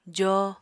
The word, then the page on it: joo: apatani